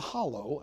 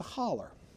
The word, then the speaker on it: hollow: The baller from the hollow, or as we pronounce it, the holler.